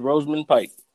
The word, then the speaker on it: Rosamund Pike: I thought you pronounced it another type of way, but like, nah, it's Rosamund Pike.